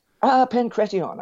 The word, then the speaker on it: Pancration: Now, is boxing different from pancratian? Did I pronounce that correct? – Pancration, I think. Yeah, Pancration.